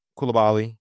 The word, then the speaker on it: Koulibaly: Koulibaly. I'm pretty sure it's Koulibaly. It's the French pronunciation, but I'm going to double check that.